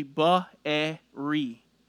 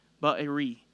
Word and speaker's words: Beeri: The word of the Lord which came to Hosea, the son of Beeri. If you want to say it more accurately, I'm going to say it slowly, Ba-e-ri. Ba-e-ri. It's actually three syllables.